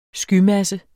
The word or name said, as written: SKYMASSE